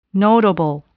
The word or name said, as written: notable